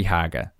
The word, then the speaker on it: Hager: I think everyone says his name wrong. Hager. It's Nikki Hager. I'm 99% sure on that.